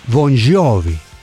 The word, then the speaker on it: Bon Jovi: Su apellido paterno tenía una pronunciación más o menos italiana, Bon Jovi.